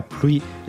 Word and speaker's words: pluie: Com es pronuncia? – La pluie és el la substantiu, pluie. però pleuvoir és més complicat.